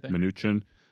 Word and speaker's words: Mnuchin: Am I pronouncing that right? – Mnuchin.